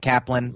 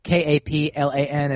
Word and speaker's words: Kaplan: My name is Myq Kaplan. Myq is spelled M Y Q K A P L A N is Kaplan.